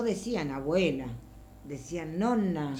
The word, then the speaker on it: nonna: Nosotros tal vez lo decíamos con una N sola, pero en italiano vero, puro vero, es doppia N, en el final. Sería nonna, sí. Nonno, porque las doppias se pronuncian.